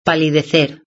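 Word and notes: palidecer: Spanish (Spain)

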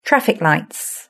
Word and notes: traffic lights: British English

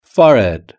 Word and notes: forehead: British English

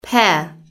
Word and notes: pear: British English

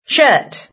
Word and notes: shirt: British English

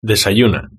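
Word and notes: desayuna: Spanish (Spain)

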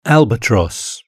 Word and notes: albatross: British English

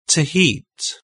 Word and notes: to heat: British English

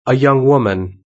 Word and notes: a young woman: British English

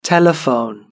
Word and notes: telephone: British English

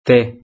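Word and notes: te: Spanish (Spain)

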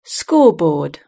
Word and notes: scoreboard: British English

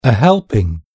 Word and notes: helping: British English

